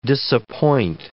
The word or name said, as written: disappoint